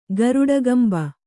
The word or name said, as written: ಗರುಡಗಂಭ